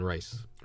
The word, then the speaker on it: Reis: I always say Reese or Rice, R-E-I-S. – I've always heard it pronounced Ivan Rice.